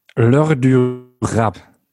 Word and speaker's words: L'Heure du Rap: Außerdem machst du aktuell auch eine, ich weiß gar nicht, kommt sie wöchentlich raus, eine französische Rap-Stunde, L'Heure du Rap. – Genau, zweiwöchentlich. – Zweiwöchentlich. Habe ich es französischmäßig richtig ausgesprochen oder war es ganz fürchterlich? – L'Heure du Rap.